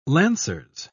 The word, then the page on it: lancers: British English